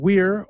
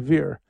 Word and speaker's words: vir: Like, you can pronounce the word V-I-R, which means man, weir, or veer. I use the Italianate or the church pronunciation, veer. The other one always sounds so affected.